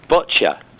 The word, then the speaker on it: Boccia: I hope I'm going to pronounce this right. What is Boccia? – It's Boccia. – Boccia. – Boccia, yeah. – I knew I was going to say that wrong.